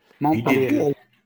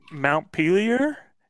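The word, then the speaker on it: Montpelier: How do you say that word? – Montpellier. – I was like, Mount Pelier?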